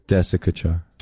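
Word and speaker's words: Desikachar: TKV, around that same time, TKV Desi Kachar. Am I saying that right? – Desi Kachar.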